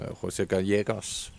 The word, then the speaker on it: José Gallegos: en spiller som José Gallegos, hvis det ellers er udtalt rigtigt, det er det.